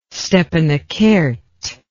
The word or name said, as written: stepanakert